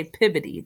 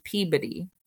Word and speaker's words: Peabody: Peabody. So they don't say Peabody. They say Peabody. – And Peabody, yeah. – Essex is pronounced the way I would say Essex, E-S-S-E-X.